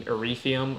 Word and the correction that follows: Ethereum: however you say Ethereum? – That. Ethereum. I can get behind that.